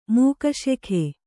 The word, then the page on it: ಮೋಕ್ಷಕ: mōkṣaka